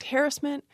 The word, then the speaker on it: harassment: So historically, the stress was on the first syllable, harass and harassment. In American English, the stress started moving to the second syllable. And over the course of the 20th century, that pronunciation has become the most common.